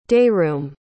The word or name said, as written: dayroom